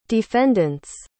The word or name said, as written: defendant's